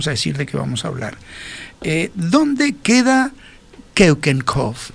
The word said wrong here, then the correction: Keukenhof: Repito, Keukenhof. Keukenhof se escribe K-E-U-K-E-N de Natalia H-O-F.